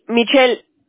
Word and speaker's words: Michel: Y Michelle, ¿cómo se escribe tu primer nombre? – Mi nombre es francés. Michelle. M-I-C-H-E-L.